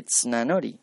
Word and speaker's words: Tsunanori: Y Tsunanori, pues casi que se pronuncia solamente Tsunanori, pero bueno.